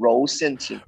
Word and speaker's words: Rou Xian Jing: Your last drop was the EP Rou Xian Jing. Did I say that correctly? – It's actually called Rou Xian Jing.